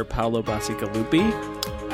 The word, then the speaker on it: Paolo Bacigalupi: Paolo Bassigalupi.